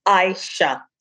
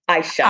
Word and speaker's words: Ishea: So beginning, I just want to correct you on the pronunciation of my name. It's Ishea. Ishea.